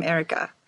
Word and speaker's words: Erika: How do you say your name in English? Erika, or do you say, I'm Erika? – I'm Erika. – I'm Erika. Okay. – Like, in a very Swedish way, because then it's my last name.